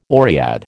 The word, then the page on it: oread: American English